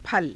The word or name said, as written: phala